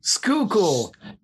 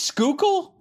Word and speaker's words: Schuylkill: And you were close. It's pronounced Schuylkill. – Schuylkill. – This is Schuylkill? – Yep.